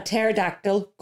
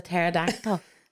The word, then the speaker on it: pterodactyl: Why can't you hear a pterodactyl going to the bathroom? – What is a pterodactyl? – Dinosaur. – Okay. – With wings. Because the P is silent. Because it's spelt P-T-E-R-O-D-A-C-T-Y-L.